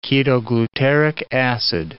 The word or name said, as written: ketoglutaric acid